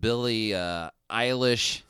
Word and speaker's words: Billie Eilish: The whole Van Halen, Billie Eilish, that's how you say her name, right? – Yes.